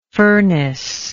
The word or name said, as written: furnacelike